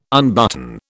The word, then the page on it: unbutton: British English